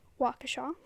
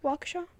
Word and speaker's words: Waukesha: It was in a western suburb of Milwaukee, Wisconsin, known as Waukesha.